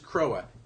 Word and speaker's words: Krowa: So, A is Pit River, B is Crow, spelled like the bird, and C is Croa, K-R-O-W-A.